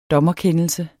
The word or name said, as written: dommerkendelse